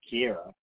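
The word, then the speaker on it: Ciara: I think it's C-I-A-R-A, so I think, yes. Yeah, Tierra. So, it's pronounced Kiera. I've also seen it spelled K-I-E-R-R-A, so Kiera.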